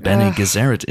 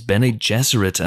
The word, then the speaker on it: Bene Gesserit: Well, Helen, I have to, of course, get my Dune-splaining hat onto you and correct you in your pronunciation of Bene Gesserit. It's Bene Gesserit, as we all know.